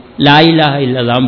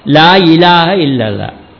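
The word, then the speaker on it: லா இலாஹ இல்லல்லாஹ் (لا إله إلا الله): இதில் நிறைய பேர் பல சொல்கிறான் அந்த பாங்கலாம், லா இலாஹ இல்லல்லாஹ், லா இலாஹ இல்லல்லாம் பாங்க, லா இலாஹ இல்லல்லாஹ் சரியாக உச்சரிக்கணும், லா இலாஹ இல்லல்லாஹ்.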